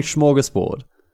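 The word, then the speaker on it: smörgåsbord: Welcome to our northern and it's actually a smorgasbord because the A with a little O above it makes an O sound.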